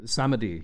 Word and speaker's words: Samedi: I actually did not get to throw in that my favorite part of this movie also was Jeffrey Holder as Baron Samedi, I think is how you say his name. – Yeah, Samedi.